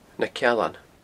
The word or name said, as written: Na Cealan